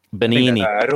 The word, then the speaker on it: Benigni: Am I saying that right? I know he's one of your favorites. Roberto Benigni? – Benigni. – Roberto Benigni. Yes, that's Roberto Benigni.